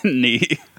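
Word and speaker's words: née: Is that how you say it? I always said nay in my head, like a horse.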